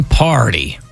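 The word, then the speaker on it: Pardi: Had a bunch of hearty answers. Rhymes with parties. It. But not party answers.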